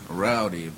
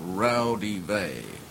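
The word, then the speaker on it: Raudive: And the most complete source book is a book called Breakthrough by Constantine Rowdy. That's actually pronounced Rowdy Vey. It's Latin.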